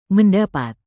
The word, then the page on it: mendapat: indonesian